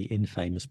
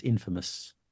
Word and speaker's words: infamous: You are famous and you want to change the meaning by putting I-N, and you'd think it's going to be infamous, but it's not. It's infamous.